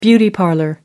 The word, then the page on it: beauty parlor: British English